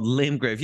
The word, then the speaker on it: Limgrave: that's what it's called, Limgrave.